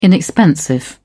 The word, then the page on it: inexpensive: British English